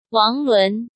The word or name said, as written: Wang Lun